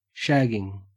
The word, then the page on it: shagging: Australian English